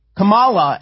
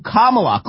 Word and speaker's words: Kamala: No, no, the wrong syllable. Oh, my God, she calls her Kamala as opposed to Kamala.